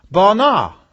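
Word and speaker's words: bana (בָּנָה): This is the word bana. Bana. Pronounced B-A-W-N-A-W.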